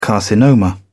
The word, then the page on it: carcinoma: British English